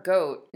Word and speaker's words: GOAT: Or is it G-O-A-T? – G-O-A-T, it's pronounced goat, but it's greatest of all time.